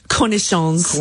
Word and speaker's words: cornichons: is it cornichon? Is this how we pronounce it? – Cornichons.